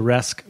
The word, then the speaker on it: Resque: It's funny you mentioned, you know, the pronunciation of Rescue there with I guess your French, so Resque would be the French pronunciation.